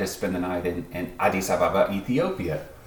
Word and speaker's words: Addis Ababa: I like to say it like all one word. Addis Ababa. – That's probably more accurate to, I would say, how the local people say it.